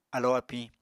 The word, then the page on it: alo apiñ: apatani